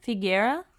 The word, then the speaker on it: La Font de la Figuera: Is that how you pronounce it? – Figuera. – Oh, it sounds so much better when you say it.